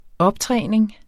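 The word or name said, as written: optræning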